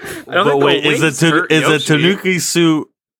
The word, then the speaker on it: Tanuki: We have the Tanuki.